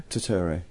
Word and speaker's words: Turturro: Is that how you pronounce his name? – Turturro? – Turturro, yeah. Him.